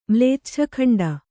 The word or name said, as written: ಮ್ಲೇಚ್ಛಖಂಡ